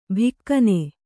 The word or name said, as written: ಭಿಕ್ಕನೆ